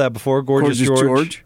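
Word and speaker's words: Georges: Okay, how about George Niang? How about Silent S or Silent Night? Because you don't, the S isn't pronounced.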